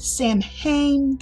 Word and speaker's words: Samhain: That's S-A-M-H-A-I-N. You may see people pronounce it Samhain. That is incorrect. It is Samhain.